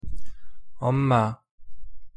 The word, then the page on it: 엄마: Korean